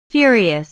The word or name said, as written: furious